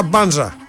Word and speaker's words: Μπάνζα: Ακούσατε στην αρχή, Μπάνζα ο ίδιος, και καλά κάνει εδώ ο ΠΑΟΚ και τον αφήνει να συστηθεί, να πει ο ίδιος πως προφέρεται. Άρα Μπάνζα, όχι Μπάνζα, τον παίρνουμε τον τίτλο από τη Λίγουσα και τον πάμε σε αυτό, πως λέγεται το προηγούμενο, Παραλίγουσα, ξέρω εγώ.